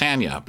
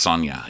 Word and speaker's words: Sanya: It was Tanya, and so I see Sanya spelled like Tanya, and I'm like, well, it's Sanya, but no, it's Sonya.